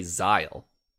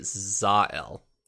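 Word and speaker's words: Zahel: So it's Z A H E L, and they say Zile, But a lot of people say Zael, like they like Zael. – Yeah, Zael, like, like two almost two words.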